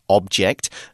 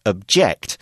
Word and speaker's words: object: Here we've used object as a verb, and it's pronounced differently. As a noun, it's object. As a verb, it's object.